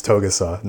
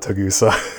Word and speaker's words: Togusa: It's Togusa, not Tagusa.